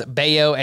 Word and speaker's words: Bello: that's spelled B-E-L-L-O. So it's going to look like Bello, but it's actually pronounced Baio,